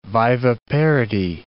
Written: viviparity

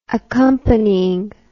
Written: accompanying

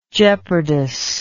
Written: jeopardous

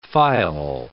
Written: faille

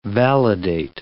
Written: validate

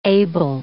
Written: Abel

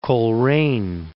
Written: Coleraine

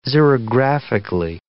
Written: xerographically

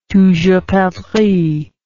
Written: toujours perdrix

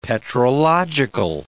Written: petrological

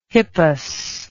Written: hippus

